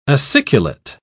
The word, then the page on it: aciculate: British English